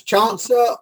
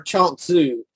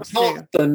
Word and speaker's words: Chuang Tzu: It's Chuangsa or Chuangsu. Either Chuang one. Chuangsa normally is the way I'm learning.